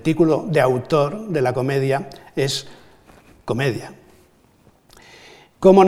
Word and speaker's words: comedia: por la escansión hay que pronunciar comedia, que era palabra para entonces culta, pronunciado a la griega, ese es el único título que Dante reconocería como definidor de su obra.